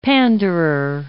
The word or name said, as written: panderer